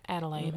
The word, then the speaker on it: Adelaide: So I grew up in a big Victorian house in Adelaide, Australia? – Adelaide.